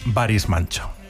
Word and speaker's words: Barış Manço: es Barış Manço.